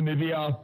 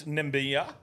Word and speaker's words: Namibia: New Zealand, Namibia. New Zealand pumped them. – Mate, I'm going to pull you up there. It's Namibia.